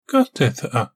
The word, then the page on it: Gatetha: Galindri